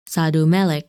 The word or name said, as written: Sadal Melik